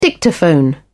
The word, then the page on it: dictaphone: British English